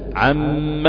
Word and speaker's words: عم: اول كلمة نعم، ثاني كلمة مما، نعم، كلمة عم موضع بتاع عم يتساءلون، ربنا عز وجل يقول عم يتساءلون، ما ينفعش ان كلمة عم تتقسم لاثنين، تقول عن كلمة ما وتبقى تقعد تألف فيها. لا، هي لا تنطق الا موصولة، عم يتساءلون بتشديد في الحرف الثاني، نعم مما عم اما.